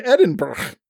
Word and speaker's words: Edinburgh: Today, you've gone beyond Edinburgh.